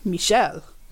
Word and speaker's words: Michel: I think a friend of mine's husband, his name is Michael, and he discovered one day that in French his name is pronounced Michel. – Michel, and he was not having that.